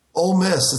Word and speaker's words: Ole Miss: How do you pronounce that? Is it just old? – Ole Miss. It's the college. It's the university I went to.